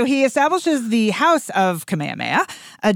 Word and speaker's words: Kamehameha: All right, wait, before I even try, Nick, do you know how to pronounce this? – Actually, I do. Don't ask me why. His name is King Kamehameha the Great.